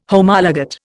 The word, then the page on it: Homologate: Indian